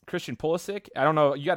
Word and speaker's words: Pulisic: Well, I think you got the name right. It is Christian Pulisic, so good job on that one.